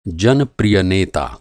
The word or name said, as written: जनप्रिय